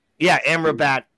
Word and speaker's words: Amrabat: Amrabat? – Amrabat.